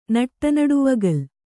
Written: ನಟ್ಟನಡುವಗಲ್

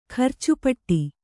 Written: ಖರ್ಚುಪಟ್ಟಿ

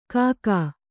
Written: ಖಗ

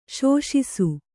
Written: ಶೋಷಿಸು

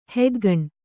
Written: ಹೆಗ್ಗಣ್

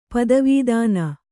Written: ಪತಿವತ್ನಿ

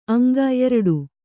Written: ಅಂಗ

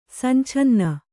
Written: ಸಂಛನ್ನ

